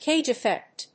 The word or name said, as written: cage effect